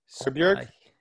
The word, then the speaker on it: Højbjerg: It was Højbjerg.